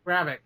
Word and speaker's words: Gravik: I'm going to say this too, and this kind of goes into episode two, but the villain for this show, Dervik, I believe is how they pronounce it. Gravik, yeah.